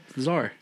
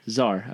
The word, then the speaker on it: Czar: Czar. – Czar, okay.